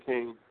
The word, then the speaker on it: Kahn: What Kelly set up, and he just sent a link about Eddie Kane. It's spelled K-A-H-N. It's pronounced Kane.